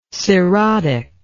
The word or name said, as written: cerotic